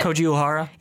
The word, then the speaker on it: Uehara: Koji Uehara.